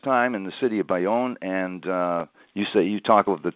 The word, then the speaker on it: Bayonne: Now you take us in the book to December 18th and 1958, and tell me if I'm mispronouncing this, it's Bayonne, New Jersey. – Bayonne, correct, yes.